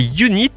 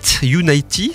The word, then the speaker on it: UNI'T: Et le deuxième titre, euh, c'est Unit, Unity, c'est ça plutôt, même ça se prononce comme ça.